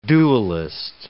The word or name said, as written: duellist